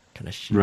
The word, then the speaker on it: Kaneshiro: Ra or Ro? Kaneshiro. – Ro.